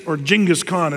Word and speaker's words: Genghis Khan: Genghis Khan is actually how you say it.